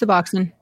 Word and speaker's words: Suboxone: I might be mm-hmm. mispronouncing that. I'm sorry if it did. It's Suboxone.